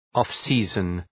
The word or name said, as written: off-season